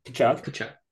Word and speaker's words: Tkachuk: Tkachuk.